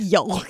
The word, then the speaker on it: yolk: No one would say yolk. Like it's the L is there, but I don't sit there and say an egg yolk.